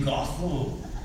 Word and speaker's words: Gothel: That's the garden of Dame Gothel. Rhymes with brothel.